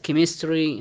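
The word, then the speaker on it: chemistry: أما اللي سألوا عن كلمة السي و اتش تنطق بحرف الكاء، حالات شاذة وقليل من الكلمات التي تنطق بحرف الكاء، يعني مثل ما تفضلت كلمة سكول وكلمة كروم وكلمة كيمستري، هذه كلها تأتي سي اتش سي اتش مباشرة، يأتي بعد السي اتش لكنها تنطق حرف الكاف.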